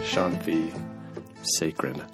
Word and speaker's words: Shanthi Sekaran: Shanthi Sekeran. – Yes. – Yeah. Nice pronunciation.